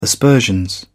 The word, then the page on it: aspersions: British English